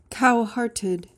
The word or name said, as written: cowhearted